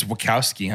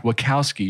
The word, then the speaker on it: Wachowski: One of the things that stood out to me is that we've got the Wachowski. Am I pronouncing that right? – I think it's Wachowski, huh? – Wachowski, you're right.